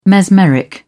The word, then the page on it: mesmeric: British English